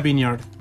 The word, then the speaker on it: Vineyard: Hay toda una discusión ahí con eh, Marcela Amaya acerca de cómo se dice Vineyard. – Tenía razón, era Vineyard.